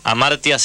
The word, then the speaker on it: Hamartia: La revista se llama Amartya. Amartya se escribe con H al principio y después Amartya, como amar a la tía.